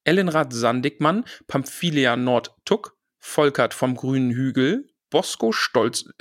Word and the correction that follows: Boso: Äh, oh, jetzt habe ich Boso falsch ausgesprochen. Oh Gott, oh Gott, jetzt krieg ich eins auf den Nuss. Mm. Oh, oh Gott, oh Gott. Boso Stolznacken.